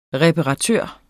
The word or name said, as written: reparatør